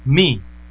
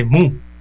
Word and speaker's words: Mu: Bueno, aquí la conocen como la variante Mu. Es que esa letra, la U, se pronuncia como I en el alfabeto griego. Por eso escuchará algunos medios que le dicen variante Mi aunque se escriba MU.